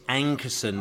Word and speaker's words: Ankersen: Um, you know, um, Rasmus Ankersen, is that how you pronounce it? It's Ankersen. Oh, There's no no other letters thrown in there.